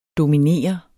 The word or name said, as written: DOMINEREDES